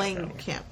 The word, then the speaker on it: Langenkamp: Yeah, Langenkamp.